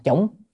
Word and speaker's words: chủng: chúng sanh đó. Trong đó có khi có chúng ta chủng chư thiện căn, chỉ chủng hay là đọc là chúng, nhưng mà thông thường đọc chủng, chủng là trồng, chư thiện căn là trồng nhiều thiện căn, trồng rất nhiều cái căn lành.